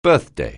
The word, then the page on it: birthday: anglais